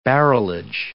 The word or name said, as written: barrelage